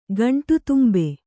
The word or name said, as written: ಗಂಟುತುಂಬೆ